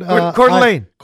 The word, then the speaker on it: Coeur d'Alene: Coeur d'Alene, is that how you pronounce it? – Coeur d'Alene, Idaho.